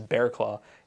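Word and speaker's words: Barraclough: Now, I think most people probably know Fernando Rodney, but Kyle Bearclaw, and I'm almost positive that's the right way to pronounce that name, is Bearclaw.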